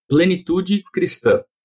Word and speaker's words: Plenitude Cristã: And I first of all, help me to pronounce the name of your church in Portuguese correctly. – All right. It's Igreja, church, right? Plenitude Cristã.